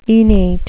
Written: enate

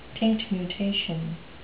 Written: tinctumutation

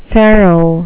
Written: pharaoh

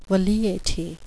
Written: velleity

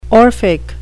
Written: orphic

